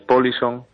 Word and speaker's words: polisong: Es una palabra sueca que significa patillas. Su pronunciación es Polisong